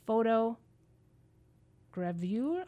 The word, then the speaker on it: photogravure: He uses an interesting medium of expressing his photographs called photo gravure. How do you say it? – Photogravure. – Let it roll off the tongue.